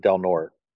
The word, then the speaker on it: Del Norte: Of course, at this point, the county I live in Northern California is spelled D-E-L space N-O-R-T-E, and everybody pronounces it Del Norte.